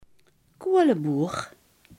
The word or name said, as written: kolenboer